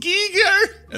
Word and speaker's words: Giger: And he'd say, it is pronounced Giger.